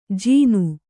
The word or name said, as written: ಜೀನು